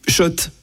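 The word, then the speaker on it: Schott: Oui, Shot et non, pas Scott. Scott. Ça fait des années qu'on prononce mal la marque.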